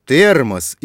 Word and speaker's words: термос: И запомни, в словах «термос», «энергия», «пюре» тоже звучит «э». А вот пишется в этих словах буква «е».